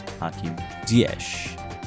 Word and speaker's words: Ziyech: You pronounce it as Hakim Ziyech.